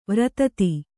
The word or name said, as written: ವರ್ಧಿತೆ